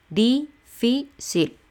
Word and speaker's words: difícil: Difícil tres sílabas, el acento va en la penúltima. Les repito, la última sería el sil, la penúltima sería el fi, difícil.